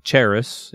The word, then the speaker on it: charis: grace or charis in the Greek.